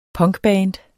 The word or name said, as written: punkband